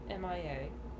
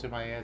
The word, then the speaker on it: M.I.A.: Yeah, it's just M.I.A. It's not Mia. – Oh. – The girl's name is not Mia. It's you pronounce it M.I.A.? – Yeah. – As if she were missing in action? – Yeah.